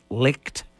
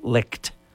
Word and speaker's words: Licht: You know, some of the new leaders, I don't know if the guy's name is pronounced Licht. It's C-H-T, not K-E-D, but I think it's Licht.